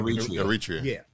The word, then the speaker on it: Eritrea: Eritrea.